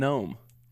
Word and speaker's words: gnome: Right, like the G in Gnome.